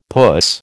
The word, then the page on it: puss: American English